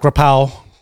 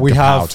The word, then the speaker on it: Krapao: So from top left to right, we have Pad Krapao. – Okay. – Which is my Thai comfort food. – It's like kapow to white people, right? – Yeah. – That's how you say it.